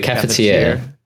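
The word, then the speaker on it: cafetière: The Cafetiere.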